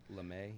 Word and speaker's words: Lamet: I think it's like LeMay.